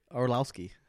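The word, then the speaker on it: Arlovski: Arlarski. No. How you say it? – Arlowski. – God damn. Arlowski. – There's an L in there. Just think about that. Arlowski.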